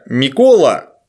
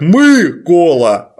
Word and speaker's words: Микола: Например, имя Микола, то есть Николай, по-украински пишется через «и», которое по-украински же читается как «ы», и поэтому «Микола» по-украински звучит как «мы-кола», а не как не «ми-кола».